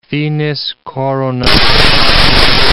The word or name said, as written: finis coronat opus